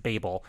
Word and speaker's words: Babel: I always love getting that right. I always feel so good when I can pronounce it Babel.